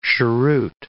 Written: cheroot

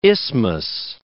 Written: isthmus

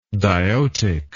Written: diotic

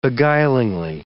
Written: beguilingly

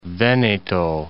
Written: Veneto

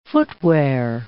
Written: footwear

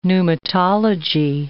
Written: pneumatology

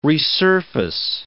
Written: resurface

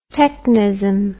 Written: technism

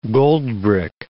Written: goldbrick

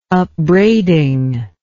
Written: upbraiding

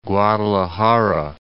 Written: Guadalajara

